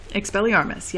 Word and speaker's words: Expelliarmus: Help me with pronunciation. Expelliarmus? – Expelliarmus, yes.